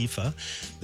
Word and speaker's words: Aoife: Her name is Irish. It's pronounced Aoife.